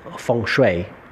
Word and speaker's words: Feng Shui: Este simbolismo que es el, el Pacua también conocido en el Feng Shui o mejor eh, pronunciado Feng Shui, también es una marca.